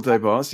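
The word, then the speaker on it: Des Barres: Yes, Michael Debars, yeah, yeah, great man. – The S is silent, by the way, it's Debar.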